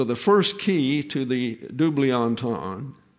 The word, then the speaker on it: double entendre: The warning contains a double entendre, is the way that's pronounced. Double entendre, an ambiguity of meaning arising from language that lends itself to more than one interpretation.